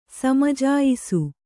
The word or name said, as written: ಸಮಜಾಯಿಸು